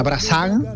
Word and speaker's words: Brassens: Se escribe, ajá, Brassens, pero se pronunciaría Brassan.